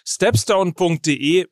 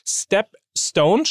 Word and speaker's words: Stepstone: Also, ihr könnt alles planen, Wunschgehalt eingeben, Schritt für Schritt euch entwickeln und das funktioniert am besten eben auf stepstone.de mit dem Gehaltsplaner und deswegen müsst ihr euch auch genau diese Adresse merken. Step Stone schreibt man S-T-E-P und dann Stone wie Stein, äh, wie Stein in Englisch.